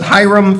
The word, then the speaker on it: Hiram: Verse 13, now King Solomon sent and brought Hiram, and that's how you pronounce it, Hiram from Tyre.